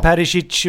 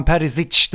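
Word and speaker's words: Perišić: Säger du inte Perisic utan Perisic, då får du ju själv liksom.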